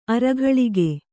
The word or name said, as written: ಅರಗಳಕ